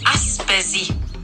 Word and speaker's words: Aspesi: Tänkte bara tala om att det heter Aspesi med B. betoningen på iet och inte specie.